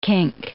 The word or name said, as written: kink